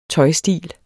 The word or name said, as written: tøjstil